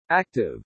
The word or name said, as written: ACTIVE